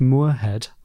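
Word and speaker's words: Muirhead: And Robert Moorhead, I think that's how you pronounce his name. – M-U-I-R. – Yeah. Yeah, there you go.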